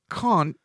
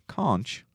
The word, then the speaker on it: conch: Conch, all right, has two different pronunciations. They are both widely accepted. The first is to say conch, mm-hmm. and more widely used is conch. – There you go. – So that is conch.